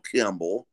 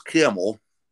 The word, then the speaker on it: Campbell: It's a little town called Campbell, for the locals, Camel.